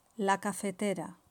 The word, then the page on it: la cafetera: Spain Spanish